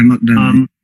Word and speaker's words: Magdala: I think it's Magdala, or I don't know how you pronounce it. – Yeah, Magdala.